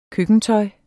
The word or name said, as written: køkkentøj